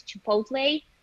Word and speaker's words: Chipotle: A brand I checked recently is Chipotle.